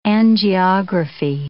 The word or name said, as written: angiography